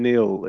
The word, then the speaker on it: Nael: It's N-A-E-L. How do you pronounce it? – Actually, both Nael and Neil. It's spelled N-A-E-L because it's an Arabic name. But my parents are, my mother's Palestinian and my father's English. So in England, I was always called Neil.